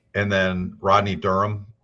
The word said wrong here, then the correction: Dunham: that was easy. – No, it's Dunham. You were so close. – Dunham, okay.